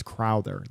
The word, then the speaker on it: Crowther: I said Crowder. It's Crowther. That was the other thing.